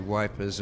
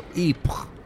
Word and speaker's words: Ypres: Wipers is the colloquialism, the local, the British soldiers, I should say, used for Ypres, which is the correct pronunciation, so I'm told. But the British couldn't get their tongues around that, so they decided to call it Wipers.